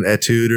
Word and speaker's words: etude: Like an etude or something like that.